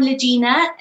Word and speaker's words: Lajina: So I'm Lajina, which is spelled L Lima Alpha Jemima India November Alpha.